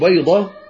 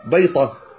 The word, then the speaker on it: بيضة: ولذلك سبحان الله هناك من يخلط بين الطاء والدال، ولكن غالبا ينطقون الضاء دالا مفخمة فيخلطون بين الطاء والدال المفخمة، كقول بعضهم بدل أن يقول بيضة بيطة، أهل شمال المغرب لا يقولون بيضة يقولون بيطة، إلى أحرف يعني أخرى.